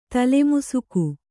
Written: ತಲೆಮುಸುಗು

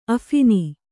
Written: ಅಫಿನಿ

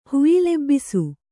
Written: ಹುಯಿಲೆಬ್ಬಿಸು

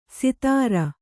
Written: ಸಿತಾರ

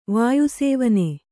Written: ವಾಯುಸೇವನೆ

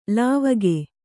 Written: ಲಾವಕ

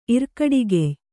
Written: ಇರ್ಕಡಿಗೆಯ್

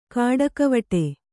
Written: ಕಾಡಿಗೆವಿಡಿ

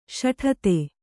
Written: ಸೋಡ್ತಿ

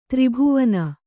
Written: ತ್ರಿಭುವನ

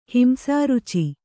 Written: ಹಿಂಸಾರುಚಿ

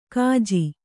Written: ಗಜ್ಜೆ

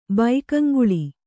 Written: ಭಾಗಂಗೊಳು

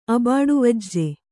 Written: ಅಬಾಡುವಜ್ಜೆ

